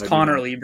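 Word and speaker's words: Conerly: A much easier name to say, Josh Conley Jr. out of Oregon. – You mispronounced it. – It's Connerly, bro.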